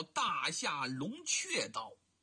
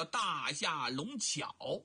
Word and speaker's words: 大夏龙雀: 不对，正音应该叫大夏龙雀刀，就麻雀那个雀，这个雀字在民间呢又被读作巧，哎，黄巧啊，对吧，家巧啊，所以呢叫大夏龙巧，其实啊就是雀，大夏龙雀刀。